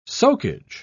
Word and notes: soakage: British English

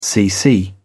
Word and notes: cc: British English

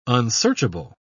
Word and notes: unsearchable: British English

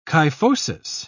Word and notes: kyphosis: British English

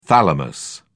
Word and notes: thalamus: British English

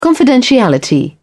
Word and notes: confidentiality: British English

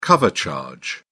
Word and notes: cover charge: British English

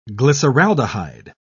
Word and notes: glyceraldehyde: British English

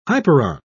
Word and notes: hyperon: British English

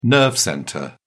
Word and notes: nerve centre: British English

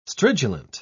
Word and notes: stridulant: British English

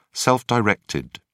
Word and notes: self-directed: British English

